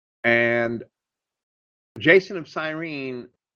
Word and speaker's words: Cyrene: And he was probably an Israelite dwelling in Cyrene, which is Kyrene, I would say, pronounce it.